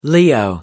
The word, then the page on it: leo: American English